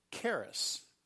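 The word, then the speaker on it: charis (χάρις): In the grace in the New Testament is the Greek word charis. C-H-A-R-I-S is the way we would spell it in English to pronounce the Greek word for grace.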